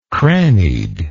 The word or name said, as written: crannied